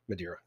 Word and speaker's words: Madeira: If I'm saying this right, the Madeira. – Madeira, that's correct.